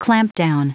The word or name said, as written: clampdown